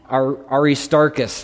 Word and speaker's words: Aristarchus: Aristarchus, there you go.